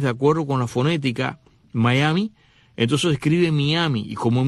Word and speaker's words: Mayaimi: Miami, que se escribe M-A-Y-A-I-M-I, Miami, Miami.